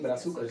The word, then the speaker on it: Brazukas: Mira, Brazucas, no sé si lo pronuncio bien. Sí, Brazucas, está perfecto, sí.